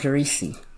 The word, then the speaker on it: Risi: Her name was Risi. – Can you spell that? – R-I-S-I. That's how I pronounce it.